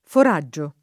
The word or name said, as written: foraggio